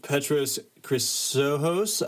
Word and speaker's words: Petros Chrysochos: All right, so today's episode, we're going to we had an interview with Petros Chrysochos.